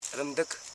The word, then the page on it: rimdik: rim.dik; Koro